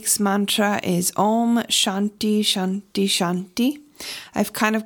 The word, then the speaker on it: Shanti: So this week's mantra is Om Shanti Shanti Shanti. And the word Shanti in Sanskrit is peace. Shanti is spelled S-H-A-N-T-I.